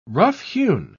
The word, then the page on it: roughhewn: British English